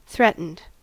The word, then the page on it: threatened: American English